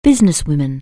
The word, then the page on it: businesswoman: anglais